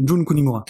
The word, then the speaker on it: Jun Kunimura: Moi, j'allais surtout citer Jin Kunimura. Jun, Jun Kunimura.